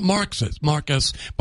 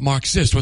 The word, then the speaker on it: Marxist: It's not Marcus, but Marxist with an emphasis on the X.